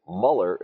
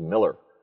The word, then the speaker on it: Mueller: But, everyone here thinks Mueller is pronounced Mueller. Oddly enough, it's a German name, and that's an anglicization of an umlaut, which would really be pronounced completely different. And Germans who came over with that name, by and large, like to be called Miller.